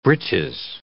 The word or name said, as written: britches